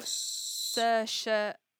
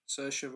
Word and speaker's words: Saoirse: Uh, Saoirse. – Saoirse Ronan.